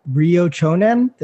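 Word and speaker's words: Ryo Chonan: For the people that don't know, like one of Anderson Silva's biggest losses was against Ryo Chonen. Did I say his name correct? – Yep.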